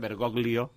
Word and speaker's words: Bergoglio: Bergoglio, segons la pronunciació italiana, Bergoglio, diuen Argentina, no? – Bergoglio, sí.